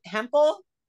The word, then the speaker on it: Hempel: It's Meghann, and I don't know if I ever pronounced your last name right, but Hempel? – Yep, you got it.